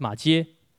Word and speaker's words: 马偕: 马马马偕不是马凯哦，是马偕。